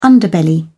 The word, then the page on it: underbelly: British English